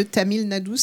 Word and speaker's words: Tamil Nadu: Et donc vous êtes allé dans un état d'Inde qui s'appelle le Tamil Nadu, c'est ça, c'est comme ça qu'on prononce.